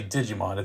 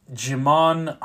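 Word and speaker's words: Djimon: I almost want to say Digimon. It's D-J-I-M-O-N. Jimon Honso, I think that's how you say his name.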